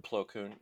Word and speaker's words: Plo Koon: And the thing, Wolf was gray, and he had a really cool helmet, and he was Plo Koon's, I believe is how you mm-hmm. say his name. – Plo Koon, yeah.